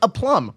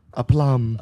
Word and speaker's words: aplomb: It sounds like you're just saying aplomb. Would you like aplomb? – Would you like aplomb?